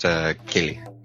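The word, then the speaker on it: Ceilidh: Just to help you out. It's pronounced Kelly.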